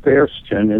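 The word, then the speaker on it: Förstchen: There's actually a little village in Germany called Forstchen. – Forstchen, okay. – In German, it's pronounced Fersten, and it means little forest.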